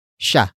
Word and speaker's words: siya: And finally, we have sha, which is spelled as S, I, Y, A.